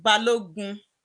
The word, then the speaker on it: Balogun: Yeah, so it's actually Balogun.